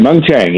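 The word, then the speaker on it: Mung Chiang: Dr. Meng Chiang, am I saying the name correctly? – Okay. Yeah, that's correct. Meng Chiang, yep.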